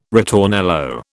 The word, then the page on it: ritornello: British English